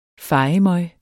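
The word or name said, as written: FEJEMØG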